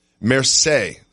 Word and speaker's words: Marseille: Marseille, I believe I'm saying that right, Marseille soccer player Adil Rami. Marseille, soccer player, excuse me. Marseille, I've been corrected in my ear. Marseille, sorry, I don't speak French.